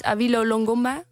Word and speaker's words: Awilo Longomba: En dit is Awilo Longomba, de Rosalina remix. Zeg ik het goed? – Ja.